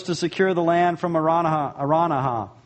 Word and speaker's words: Araunah: Somebody told me how to pronounce it. I forgot already. Araunah. It's Araunah. That's it.